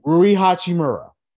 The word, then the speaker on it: Rui Hachimura: And like I said, Rui Hachimura. How do you say his name? – Rui Hachimura. It's Japanese, Bill.